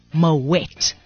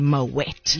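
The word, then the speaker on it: Moët: Something that made me feel really dumb just this year, beginning of the year, was realizing that I was saying Moet wrong all my life. It really? – Is actually Moet. – Yeah, you've got to pronounce the T. – Oh. – Yes. It's not Moet.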